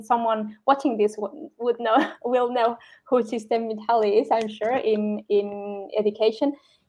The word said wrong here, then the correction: Csikszentmihalyi: Csikszentmihalyi, I know how to pronounce it. I practiced it. I wrote it down. – Okay, Csikszentmihalyi, I learned that off from a PhD.